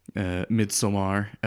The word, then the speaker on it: Midsommar: Things like The Lighthouse and Midsommar, as I recently learned that it's actually pronounced.